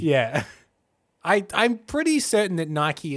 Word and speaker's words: Nike: In the classical era, and is now pronounced a bit like Nike. N-E-E-K-E. Nike.